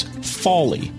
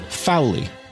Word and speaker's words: Fawley: Now, the biggest of all may be that a friend of mine who is a listener said to me, I always read F-A-W-L-E-Y, which is Jude's last name, as Folly. And I have been pronouncing it on this podcast and in casual conversations with everybody with whom I am discussing Jude the Obscure, which is literally nobody. I have been pronouncing it Fowley. And I thought her pronunciation may be better. Folly. Folly. Like awe. Folly.